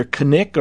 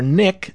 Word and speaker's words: Knik: Also grants new stations in Alaska, and its city is pronounced either Knik or Nick. It's spelled K-N-I-K, and the official city of license is Knik Fairview.